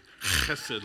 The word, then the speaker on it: chesed: If you want to say that right, you got to kind of like hock-a-loogie kind of thing. Chesed love.